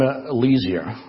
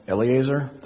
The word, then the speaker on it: Eleazar: Set before him Eleazar, I can't pronounce that, Eleazar, thank you, the priest, and before all the congregation, and inaugurate him in their sight.